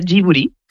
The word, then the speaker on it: Ghibli: En japonés se pronuncia Ghibli. Uh-huh. Porque ellos no tienen el sonido de la L, pero es una historia bastante graciosa porque el nombre lo elige Hayao Miyazaki y lo elige por la palabra japonés.